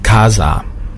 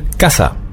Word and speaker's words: casa: Es hört sich für die Spanier fürchterlich an, wenn Sie sagen Casa. Ja, das S, das weiche S, das gibt's im Spanischen nämlich nicht. Sie müssen immer scharf aussprechen. Zum Beispiel Casa. Ja, es gibt ja viele Restaurants, die Casa Juan, Casa Maria heißen. Und wenn Sie jetzt, ja, Casa Juan sagen, das klingt wirklich fürchterlich. Ich bekomme heute noch eine Gänsehaut, wenn ich's höre. Und, bitte immer nur ein scharfes S.